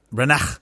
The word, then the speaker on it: Branagh: Branagh.